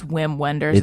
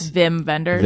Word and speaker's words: Wim Wenders: Vim. I call him, that's how you say it, right? Even though it's Wim Wenders, it's Vim Wenders.